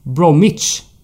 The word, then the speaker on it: Bromwich: Bromwich.